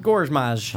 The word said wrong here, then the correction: Gorman: Gorman, say it right. – Burn Gorman.